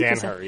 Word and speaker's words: Danaher: Danaher, yeah, that's how you say it.